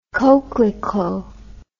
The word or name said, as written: coquelicot